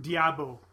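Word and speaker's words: d'Abo: Actually, I think it's pronounced Diabo. – Diabo. – Yeah, because there's the apostrophe, so you actually say D and then Abo.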